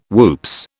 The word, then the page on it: whoops: British English